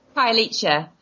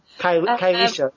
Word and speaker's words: Khayelitsha: Khayelitsha. – Khayelitsha.